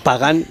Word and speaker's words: Pagán: Eh, pagan,